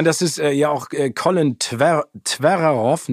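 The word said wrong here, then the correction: Trevorrow: nee, wie heißt er nochmal? Colin Trevorov. So wird er ausgesprochen, ja.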